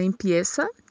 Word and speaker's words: empieza: Primeiro, na palavra empieza, que esse Z tem som de S,